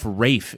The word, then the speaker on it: Ralph: Why is Ralph Rafe in your stupid, messed up version of English? – That's what it is, a stupid.